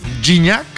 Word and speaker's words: Gignac: El paso perfecto de la selección mexicana ha sido tan arrollador que incluso el francés André-Pierre Gignac. – Ah. – Así se pronuncia, ¿verdad? – Gignac. – Que no Gignac.